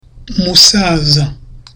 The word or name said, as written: musaza